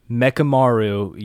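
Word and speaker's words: Mechamaru: You went, Mekamaru. – That's how you say it. – Okay. – In Japanese. Do you want me to say it the stupid English way or the smart Japanese way?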